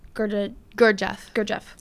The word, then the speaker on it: Gurdjieff: I'm going to butcher his last name, but Gurdjieff. – Yes. – Gurdjieff.